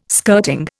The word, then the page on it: skirting: Indian